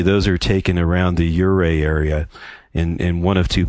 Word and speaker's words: Ouray: That's the way it's pronounced. If you look, it's O-U-R-A-Y. So you would think it was Ouray or something like that. But if you're from Colorado, you pronounce it Ouray.